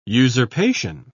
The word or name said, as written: usurpation